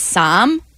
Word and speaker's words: Psalm: But I'm reading that it's actually pronounced, they want it to be pronounced Psalm. Like somber with the P Sam. And L silent.